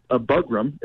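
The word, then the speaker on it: Burgum: I think it's a Bugram, if I'm saying it correctly, Mr. Yes. – Doug Bugram.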